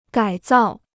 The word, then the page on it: 改造: gǎi zào